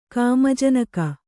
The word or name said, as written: ಕಾಮಜನಕ